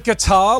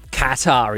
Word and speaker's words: Qatar: Is it guitar? Like guitar. A guitar? – Qatar. – No. – Qatar, isn't it? – Correct. – Oh, yeah. Qatar.